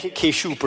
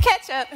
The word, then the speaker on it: Ketchup: Which means real name is going to be like Keshoop or something like that. – Okay. Well, oh no, that's pronounced Ketchup.